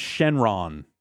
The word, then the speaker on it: Shenron: I think, so here's another crazy reference here is, so the dragon's name is Shenron. – Yes. – Which is the Japanese pronunciation, more or less, of Shenlong.